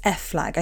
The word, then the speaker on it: FFLAG: Fortunately, I found the charity, and I think it's F Flag.